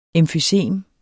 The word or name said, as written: emfysem